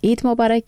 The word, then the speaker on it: عید مبارک: عربان عین واضح تلفظ کوي ځکه چې دا توري د دوی په ژبه کې شته، خو همدا مبارکي چې کله په پاکستان کې خلک ورکوي لهجه بدلیږي. – عید مبارک.